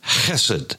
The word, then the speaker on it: chesed: It is the word chesed, C-H-E-S-E-D. The C-H is hard. Sometimes it would be pronounced chesed because our English palate doesn't have the guttural, the ch, as many of the Middle Eastern Hebrew and Arabic, the Semitic languages have.